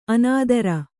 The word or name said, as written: ಅನಿತರ